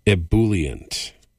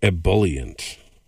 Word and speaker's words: ebullient: Ebullient, or ebullient, E-B-U-L-L-I-E-N-T. So pronounce it the way you like.